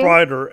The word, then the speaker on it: Spriter: Yes, yeah, Spriter. S-P-R-I-T-E-R. – Yeah, that's how I spell it.